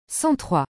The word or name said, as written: 103